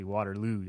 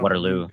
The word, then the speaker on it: Waterloo: I would have said Waterloo, but it's actually Waterloo. You, like, gotta put the emphasis on Waterloo.